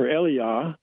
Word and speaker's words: Elijah: Which was the and this was the event that occurred right after Elijah, or Eliah, is actually a more correct pronunciation of the name, had ascended in a fiery chariot to the eternal heaven.